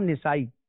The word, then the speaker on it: نسائی: بعض لوگ اس لفظ کو نسائی پڑھتے ہیں امام نسائی، تو علماء کرام نے یہاں ذکر کیا کہ اس کا درست تلفظ اور اعراب یہ ہے کہ نون اور سین دونوں پر زبر آئے گا تو نسائی نہیں ہوتا بلکہ نسائی ہوتا